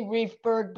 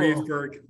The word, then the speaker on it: Riefberg: It's actually Riefberg, but it's cool. Riefberg.